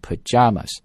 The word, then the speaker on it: pajamas: New Zealand has banned people from wearing pajamas，注意动词 ban somebody from doing something，表示的意思就是严禁或者是禁止，禁止人们呢去穿着睡衣，在这里睡衣叫 pajamas，注意这个单词的读音 py 啊，读 p 这个音，然后呢重音落在加上面 pajamas，然后注意都不要卷舌，因为在这里没有任何的一个 R 字母， So either in British accent or American English is always pajamas.